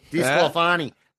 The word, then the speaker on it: DeSclafani: DeSclafani. The emphasis is on the fa.